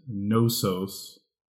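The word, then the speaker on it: Gnossos: It was actually first announced as Nosos. That's with a G in front, silent G.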